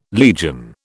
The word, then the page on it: legion: British English